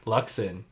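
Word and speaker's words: Luczon: Chris and my last name, Luxon. – So Chris Luxon, C-H-R-I-S-L-U-C-Z-O-N.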